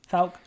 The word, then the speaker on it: Fouke: But I want to make sure in the introduction you talk about the fact that you've actually been to Fouke. Am I saying it right? – Yeah. – Fouke, Arkansas. Fouke. Let's talk about that Falcon monster.